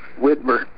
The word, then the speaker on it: Widmer: Ryan Widmer. – Say your last name for me, Ryan. – W-I-D-M-E-R.